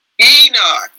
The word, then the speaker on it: Enoch: And I'm saying it wrong. The eunuch, I can't remember his name. – Enoch, yes.